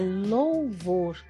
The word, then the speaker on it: louvor: Agora vamos cantar um louvor. Ops, está faltando a letra U aí. É louvor.